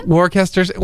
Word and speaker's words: Worcestershire: Worcestershire, well, because something shire. – Yes, but they don't pronounce it so separately. You can pronounce it as you like, I say Worcestershire.